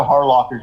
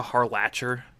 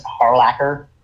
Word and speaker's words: Harlacher: You mean Erica Harlatcher? – I've always pronounced it Harlocker for some reason. – Fuck's sake. – It's dude. Last names I always pronounce phonetically. So, I've never pronounced Harlacher.